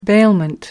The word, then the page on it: bailment: American English